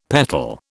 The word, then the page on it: petal: British English